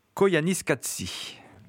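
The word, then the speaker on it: Koyaanisqatsi: c'est Koyanis Katsi. – Bravo, il a su le prononcer.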